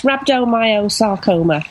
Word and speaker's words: rhabdomyosarcoma: So did I get the name right? Rhabdomyosarcoma? – Yes, I'd never heard of it either until my son was blessed with it, so to speak, in inverted commas. Yes, rhabdomyosarcoma.